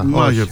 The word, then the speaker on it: Μάγερ: Θέλω κάτι να σε ρωτήσω, γιατί εγώ έχω αποκτήσει μία αδυναμία στον Λόβρο Μάιερ. Μάιερ ή Μάγερ, παρεπιπτόντως. Προφέρεται το γάμα. – Μάγερ, το ένα σου τζέι. Μάγερ τον λέμε.